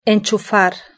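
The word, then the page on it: enchufar: Spanish (Spain)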